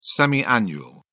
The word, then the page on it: semi-annual: British English